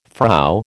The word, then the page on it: Frau: American English